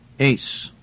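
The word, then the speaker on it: ace (εἰς): It's a three-letter word, which is pronounced ace.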